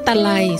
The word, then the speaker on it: ตะไล: คำว่าตะไลสะกดด้วยตอเต่าสระอะไม้มาลายลอลิงอ่านว่าตะไลตะไล